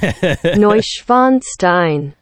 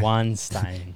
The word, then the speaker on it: Neuschwanstein: Neuschwanstein. – Yeah. – Neuschwanstein.